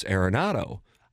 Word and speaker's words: Arenado: Guys, he said it was Arenado, pronounced like a pirate. Arg. – I like that. – I specifically remember watching it and going, oh, I've been pronouncing it Arenado. It's Arenado.